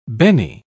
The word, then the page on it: benne: British English